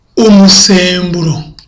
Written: umusemburo

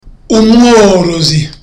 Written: umworozi

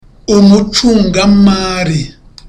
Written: umucungamali

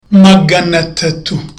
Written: magana atatu

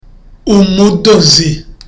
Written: umudozi

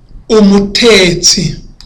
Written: umutetsi

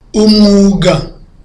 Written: umwuga

